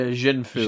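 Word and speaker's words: Xin Fu: And so he's decided to enlist our two kind of shady amateur bender fellas, Jingfu, or how do you say the name again? – Yeah, Jingfu. – Jingfu, there you go.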